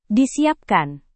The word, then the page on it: disiapkan: indonesian